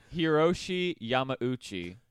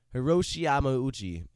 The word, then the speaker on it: Hiroshi Yamauchi: Hiroshi Yamauchi. – Hiroshi Yamauchi.